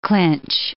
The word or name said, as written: clinch